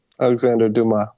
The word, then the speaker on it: Alexandre Dumas: Alexandre Dumas.